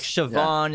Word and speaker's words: Siobhan: yeah, like Siobhan in this film, it's spelled S I O B H A N.